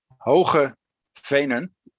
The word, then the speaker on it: Hoge Venen: The Dutch way of saying it is Hoge Venen.